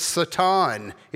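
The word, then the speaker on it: Satan (שטן): You would actually pronounce it Satan if you were pronouncing Hebrew.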